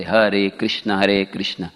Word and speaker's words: हरे कृष्ण: The word हरे कृष्ण शुड बी वेरी डिस्टिंक्टली प्रोनाउंस एंड हर्ड, स्पष्ट, जैसे हरे कृष्ण ऐसे नहीं कि प्रभुपाद जी बोलते do not make a hissing sound, हरे कृष्ण, do not make a hissing sound, instead of chanting with proper pronunciation with the help of the lips and the tongue, होठ भी हिलने चाहिए।